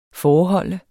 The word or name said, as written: foreholde